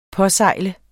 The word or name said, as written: påsejle